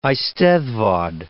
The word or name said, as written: eisteddfod